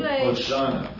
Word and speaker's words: Hosanna: Hosanna. And remember, it is the same root as the name of Jesus, Yeshua. – Hosanna. – Open body. – Hosanna to God and Lamb, forever and ever and ever.